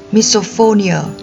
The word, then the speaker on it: misophonia: Từ này trong tiếng Anh được đọc là misophonia.